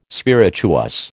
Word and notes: spirituous: American English

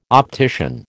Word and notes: optician: American English

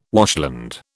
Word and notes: washland: British English

